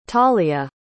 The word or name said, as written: talia